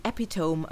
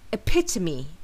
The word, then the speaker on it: epitome: I've heard many English language learners pronounce this word as epitome, as in, he is the epitome of kindness. However, the correct way to pronounce this word is epitome, epitome, with the stress falling on the second syllable.